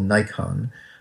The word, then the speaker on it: Naikan: It's called Nikon. We pronounce it like we actually kind of mispronounce the camera, Nikon, spelled N-A-I-K-A-N.